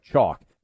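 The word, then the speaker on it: Choq: Chalk. That's C-H-O-Q, spelled with a Q.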